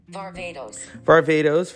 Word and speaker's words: Varvatos: John Verrat, or hold on, let's see. – Varvatos, fashion designer.